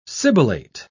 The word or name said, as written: sibilate